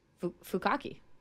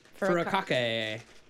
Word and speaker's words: furikake: How do you pronounce that? – Fukaki. – Furikake.